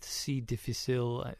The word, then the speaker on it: C. difficile: And so you end up with C. difficile. Is that how you pronounce it? – Exactly.